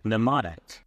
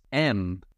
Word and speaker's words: mnemonic: So you were right with the silent letter, and you were right in the second half of the word. But you got the wrong silent letter. It is Mnemonic.